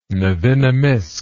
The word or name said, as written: nevinnomyssk